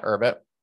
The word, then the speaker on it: Urbit: So tell me about, is it Urbit? Is that how you say it? – Yeah, Urbit.